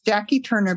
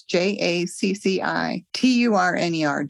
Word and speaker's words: Jacci Turner: So it's Jacci Turner, but it's J A C C I T U R N E R, Jacci Turner.